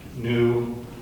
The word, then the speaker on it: pneumatikos: He is not able to understand them because they are spiritually pneumatikos, with a long O.